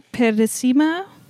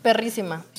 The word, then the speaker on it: Perissima: And also what's been happening is your music video please correct me if I pronounce it wrong, Perissima. – Perrissima.